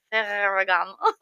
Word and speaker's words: Ferragamo: So it's been seen on multiple runways like Ferragamo, I can't roll my R's, so don't judge me. It's supposed to be Ferragamo. Can you roll your R's? Anyway, Ferragamo, just imagine me rolling my R's.